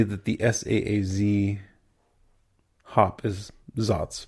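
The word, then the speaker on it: Saaz: We say Zatz because that's normally the way it's pronounced. It's spelled S-A-A-Z. I think we pronounce, I think the way we learn to pronounce it is Zatz, but some people do call it Saz.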